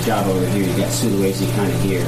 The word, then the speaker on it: Sulawesi: Sulawesi. – Sulawesi.